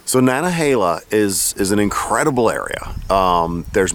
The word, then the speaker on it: Nantahala: So Nantahala, am I pronouncing that right? Is it Nantahala? – I pronounce it Nantahala. – Nantahala. – Nantahala.